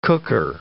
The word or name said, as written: cooker